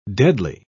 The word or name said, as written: deadly